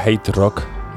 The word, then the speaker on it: HTRK: Para quien no conozca un poco la historia de esta banda HTRK, se pronunciaría Hate Rock y anteriormente fueron conocidos como Hate Rock Trio.